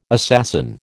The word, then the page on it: assassin: American English